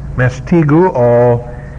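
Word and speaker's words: mastigoo: The word scourge is the word M-A-S-T-I-G-O, long O. Mastiguo, which means to whip.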